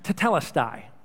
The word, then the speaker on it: tetelestai: Now, this phrase, "it is finished," is from a Greek word, a single word, pronounced tetelestai.